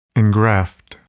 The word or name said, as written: engraft